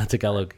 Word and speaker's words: Tagalog: Is that how you pronounce it? I've always said Tagalog. Oh, yeah. I didn't know. – Yeah, no, Tagalog. Yeah.